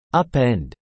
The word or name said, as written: upend